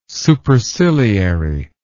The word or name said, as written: superciliary